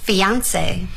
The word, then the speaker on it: fiancé: 未婚夫 Fiancé. – 这个词呢大家看到了有点发音挺怪而且那个最后的 E 上面还带一个撇儿。这是 is it English? – It's French.